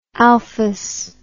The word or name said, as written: alphos